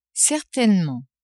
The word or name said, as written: certainement